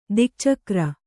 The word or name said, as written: ದಿಕ್ಚಕ್ರ